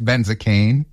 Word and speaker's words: Benzacane: It's Benzacane. It sounds like a medication.